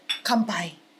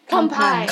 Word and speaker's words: Kanpai: Kanpai. – Kanpai.